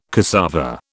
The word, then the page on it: cassava: British English